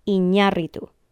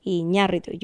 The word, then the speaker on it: Iñárritu: His name is Iñárritu, with the stress on the A. Iñárritu. You have to do the bobbing of the head on that syllable to get it right. Iñárritu.